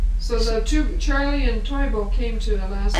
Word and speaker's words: Toivo: T-O-I-V-O. – T-O-I-V-O, Toivo.